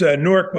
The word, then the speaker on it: Newark: And it's Newark, by the way, one syllable.